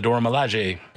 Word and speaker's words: Dora Milaje: Oh, the Dora Milaje. – There you go. There's the pronunciation, which I was scared to do, in Black Panther.